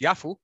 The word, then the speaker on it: Jaffa: So Jaffa, or pronounced Yafu, is an ancient port city situated in the south of Tel Aviv.